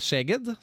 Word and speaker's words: Szeged: Flensburg møter Vardar, og så er det Szeged, som det vel uttales.